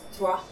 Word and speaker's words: Troyes: now I know it's pronounced Troyes, but it's spelled in a way that looks like pores in Italian.